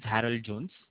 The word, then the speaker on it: Harold Jones: Yeah, my name is Harold Jones.